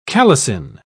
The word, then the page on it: calycine: British English